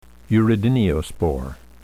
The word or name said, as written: urediniospore